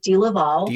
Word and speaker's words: DeLaval: It's pronounced many different ways, but I pronounce it DeLaval.